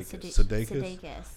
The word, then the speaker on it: Sudeikis: Sudeikis.